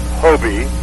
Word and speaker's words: HOBY: HOBY is pronounced Hobi, and that's short for my name, Hugh O'Brien U.